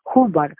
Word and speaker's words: Hubbard: Se llamaba Ruth Hubbard, se escribe con U pero se dice con A.